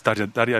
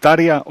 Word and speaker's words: Tarja: Que no, es Taria en solitario. – Taria Turunen. Taria, es como se dice realmente. – Taria, o no, se escribe Tarja. – Sí, pero se dice Taria.